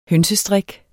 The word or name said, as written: hønsestrik